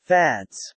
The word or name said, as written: fads